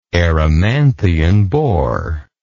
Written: erymanthian boar

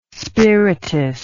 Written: spiritous

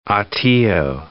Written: Hatillo

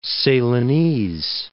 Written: Ceylonese

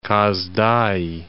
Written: Kaz Dagi

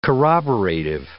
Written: corroborative

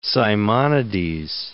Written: Simonides

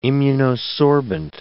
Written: immunosorbent

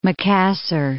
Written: Makassar

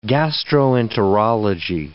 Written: gastroenterology

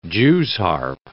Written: Jew's harp

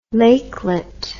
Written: lakelet

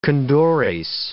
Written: condores

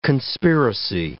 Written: conspiracy

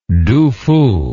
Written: Du Fu

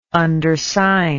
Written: undersign

